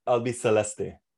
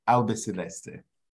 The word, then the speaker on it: Albiceleste: I got the pronunciation right? That's right, isn't it? I butchered Mundo Albiceleste. – Albiceleste.